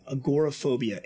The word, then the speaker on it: agoraphobia: Agoraphobia, A-G-O-R-A.